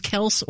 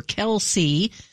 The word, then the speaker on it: Kelce: Whether Kelce should be pronounced with one or two syllables, Kels or Kelce, has been a topic of discussion.